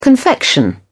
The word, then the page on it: confection: British English